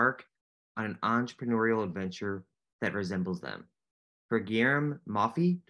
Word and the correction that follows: Mauffrey: am I pronouncing that correctly? – Yeah, Moffret. – Moffret.